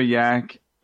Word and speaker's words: Jak: And remember, it's the Cosmere, and if your name starts with a J, it's going to be pronounced with a Y. And I think Yak might actually be short for Yakov, which is like the Hebrew and Russian pronunciation of the name Jacob. So just just uh, when you hear about Yak, just think Jacob.